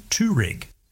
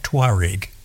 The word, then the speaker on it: Touareg: but everyone at Volkswagen, and indeed virtually everyone everywhere, pronounces Touareg. So they lose one whole syllable in there. So it's the Volkswagen Touareg.